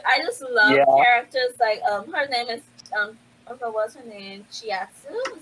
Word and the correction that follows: Chisato: Is that how you pronounce her name? – Chisato. I'm like, I can't remember. Yes. Chisato. I can't remember. – Chisato. I think it is Chisato.